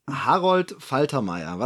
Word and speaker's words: Harold Faltermeyer: Ich neige dazu, ihn Englisch auszusprechen, obwohl es ein deutscher Name ist. Harold Faltermeier. Was sagt ihr das denn?